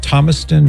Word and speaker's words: Thomaston: Do I have it right? Thomaston? Did I say it right? – Yes, you do, Patrick.